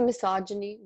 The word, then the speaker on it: misogyny: Just the misogyny, is that how you pronounce it? – Yes. Yeah.